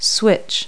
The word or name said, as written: switch